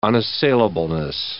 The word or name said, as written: unassailableness